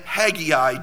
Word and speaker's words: Haggai: because I'm not even sure if it has two or three syllables. So I looked it up, and Haggai does have three syllables.